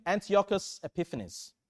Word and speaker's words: Antiochus Epiphanes: Antiochus Epiphanes.